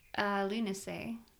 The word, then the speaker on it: Lughnasadh: L-U-G. – L-U-G-H-N-A-S-A-D-H. – Lunacy. – Lunacy. – Oh. Oh. – Is that how you would say that? – Yeah. – Lunacy.